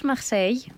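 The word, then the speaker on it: Marseille: Ολυμπιακός Μαρσέιγ. – Ναι. – Εσύ τώρα το λες για την γαλλική προφορά. Δεν μπορώ να το πω βλάχικα.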